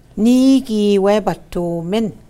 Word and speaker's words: Ni-giiwebatoomin: Ni-giiwebatoomin. Ni-giiwebatoomin. Ni-giiwebatoomin. Ni-giiwebatoomin. Ni-giiwebatoomin. So there's six syllables there.